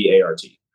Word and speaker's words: Hobart: So my handle on Twitter is it's THEE, and then Mike, and then Hobart, which is, like, you spell my last name exactly how it phonetically sounds, Hobart.